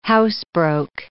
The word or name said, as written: housebroke